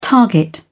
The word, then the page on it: target: British English